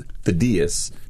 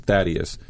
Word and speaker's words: Thaddaeus: So my name is actually pronounced Thaddeus, which is the Greek pronunciation, versus Thaddeus. And so I got Thud in sixth grade out of Thaddeus.